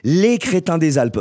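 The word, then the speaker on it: Laids Crétins des Alpes: C'était donc Drogman, morceau issu du tout dernier album des Les Crétins des Alpes. Voilà, c'est comme ça qu'on dit. Les, ça s'écrit L-A-I-D-S, Les Crétins des Alpes.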